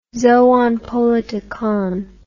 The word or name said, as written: zoon politikon